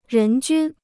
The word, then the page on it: 人均: rén jūn